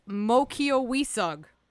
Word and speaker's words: Mokiowisug: I learned how to pronounce their name in Mohegan. It's Mokiowisug.